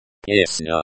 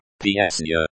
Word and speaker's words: песня: Песня is pronounced with a soft N sound, like NY in onion, making it sound like песня. The stress in the word песня is on the first syllable.